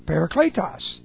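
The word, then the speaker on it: Parakletos (παράκλητος): Parakletos. That's para. Kletos. P A R A K L long E T O S. Parakletos.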